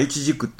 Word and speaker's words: 無花果: ほんでこれがまあ一軸っていうのを漢字で書くとですね、苗という字に花と書いて果物の果と書くわけですよね。無花果というんですか、そんなまあ漢字が一番よ当たってるらしいですね。他にもね、映画の A という字にね、火と書いて火と書くのも一軸と読むらしいです。